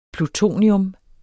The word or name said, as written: plutonium